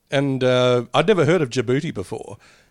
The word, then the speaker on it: Djibouti: Well, it's a place, DJBOUTI. – Oh, it's a Djibouti. – Djibouti.